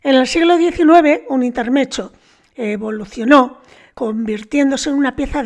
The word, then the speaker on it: intermezzo: Bueno, pues es una palabra, se escribe con 2 zetas en italiano, intermezzo, zo, intermezzo, que significa en italiano literalmente interludio. El plural se dice intermeci, acabado en i.